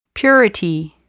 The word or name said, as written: purity